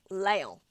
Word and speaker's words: Laon: Sorry, not Lyon, Laon. – Laon. – Different place. So Lyon is spelled L-E-O-N, and Laon is spelled L-A-O-N.